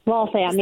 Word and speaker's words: Waltham: So listen, I want to talk a little bit about your background and upbringing in, help me if I'm pronouncing this right, Waltham, Massachusetts? – Waltham, yes. – Waltham.